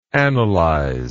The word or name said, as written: analyse